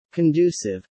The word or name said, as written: conducive